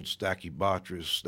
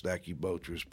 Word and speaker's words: Stachybotrys: It also comes from the fact that some of the earliest mold concerns arose over problems with a particular type of mold, Stachybotrys, Stachybotrys. People use different pronunciations, and that one happens to look black in many cases.